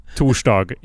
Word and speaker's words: torsdag: you know, as no one ever pronounces it. Torsdag. It's literally like yeah, it's in in Swedish it is Thor's Day. like that's how that you say it.